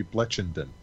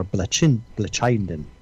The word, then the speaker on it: Blechynden: Bleckenden. – Bleck? It's with B-L-E-C-H-Y-N-D-E-N. – Brits like to pronounce the C-H. It could be Bleckenden. – Yes, or Bleckenden.